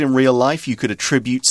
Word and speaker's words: attribute: Now, notice that when we're using attribute as a verb, we're pronouncing it differently to the noun attribute.